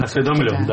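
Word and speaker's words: осведомлён: Кстати, хочу заметить, что при всем уважении к Алексею Анатольевичу Навальному, основные источники доходов Дмитрия Анатольевича Медведева, о которых Владимир Владимирович прекрасно осведомлен, или осведомлен, как правильно говорить. – Осведомлен, да.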